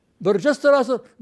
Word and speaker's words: برجستراسر: برجستراسر، برجستراسر، برجستراسر، الباء ساكنة والجيم ساكنة والسين ساكنة والساء ساكنة، برجست، أربعة سواكن، كيف يلفظونها بعدي؟